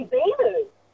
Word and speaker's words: Beelu: Probably Beeloo. – Beeloo? – Beeloo National Park. It's only up at Mundaring. But when I say Beeloo National Park, nobody knows where it is. – How do you spell it? – B-E-E-L-U.